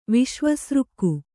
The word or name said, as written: ವಿಶ್ವಸೃಕ್ಕು